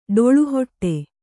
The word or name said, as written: ಡೊಳ್ಳುಹೊಟ್ಟೆ